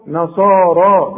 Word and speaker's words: نصارى: وبرضو الياء دي بيقول لك حولها لي الى الف ورسم لي الف فوق الياء تكتب هكذا وتنطق نصارى بالالف تهتدوا